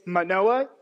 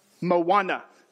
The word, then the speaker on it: Manoah: He goes, it's Manoah, not Moana. Don't get confused.